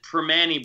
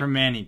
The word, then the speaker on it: Primanti: They pronounce it Permani Brothers. – Okay, like, Permani Brothers. – They drop the N and the T in Pittsburgh.